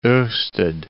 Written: orsted

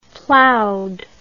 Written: plowed

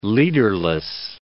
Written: leaderless

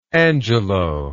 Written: Angelo

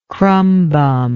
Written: crumbum

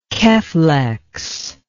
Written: keflex

